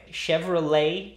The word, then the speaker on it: Chevrolet: Chevrolet, how do you say it there? – Chevrolet. – Chevrolet? – Chevrolet. – You say like lay, like this? – Chevrolet. – Chevrolet. Nice.